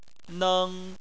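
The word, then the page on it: neng: tone 1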